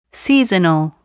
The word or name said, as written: seasonal